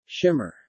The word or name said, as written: Shimmer